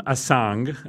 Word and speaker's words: Assange: Thus, the name Asang – and I'm saying it with a hard G